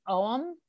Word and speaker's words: Ogham: And actually kind of interestingly, the last time I went to Ireland, there are these it's spelled O-G-H-A-M, but it's pronounced Oam.